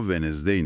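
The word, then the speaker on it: Wednesday: آره، بیایم به کلمه چهارشنبه یا ونزدی نگاه کنیم. تلفظ استانداردش ونزدی است، اما املاش طوریه که انگار یه حرف دی مزاحم افتاده وسط کلمه.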